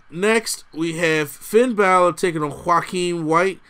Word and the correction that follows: Wilde: Is that the way you pronounce it? – Wild.